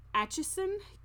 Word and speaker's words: Atchison: Located in Atchison, Kansas, said it right, the town of Atchison is actually said to be one of the most haunted in the U.S.